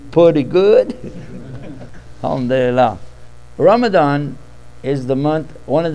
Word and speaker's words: Ramadan: And I want to pronounce it correctly. Ban, not Ramadan. Ramadan. Yeah, that's the letter that's tough on the folks.